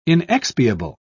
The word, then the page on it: inexpiable: British English